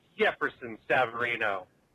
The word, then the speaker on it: Jefferson Savarino: It's Jefferson Savarino.